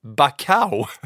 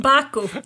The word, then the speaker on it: Baku: När vi någon gång gör ett så här best of moment, så ska jag fasen lyckas klippa ihop alla dina olika uttal på Azerbaijan och Baku. För det här var nog det bästa. Bakau. – Baku.